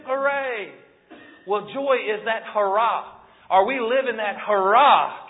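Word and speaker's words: hara (χαρά): It's spelled C-H-A-R-A in the Koine Greek. Well, the English translation of that. C-H-A-R-A, but it's pronounced hara, hara, hura. You know? That's kind of what it sounds like to me.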